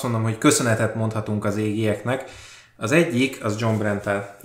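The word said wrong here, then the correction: Jon Bernthal: aki tehát Ja, Berntál. – Igen, igen, bocsánat, igen. – nem tudom kiejteni a nevét, uh, majd egyszer megtanulom. Szóval a lényeg az, hogy... Nem színász, az úgy van, John Bernthal. Oké.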